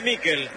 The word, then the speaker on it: Miquel: Nicolás Álvarez, usted que es especialista en nombre, se escribe Miquel, ¿cómo le digo? – Decile Miquel.